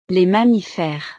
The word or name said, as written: les mammiferes